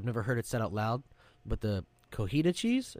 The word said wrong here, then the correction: cotija: Oh, is it cojita? Is it like, I thought it was cotija. – I'm pretty sure there's a J in there, which is pronounced with an H, I think.